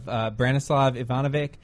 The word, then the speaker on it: Branislav Ivanovic: In addition to Fabregas, they also have Branislav Ivanovic and Nemanja Matic.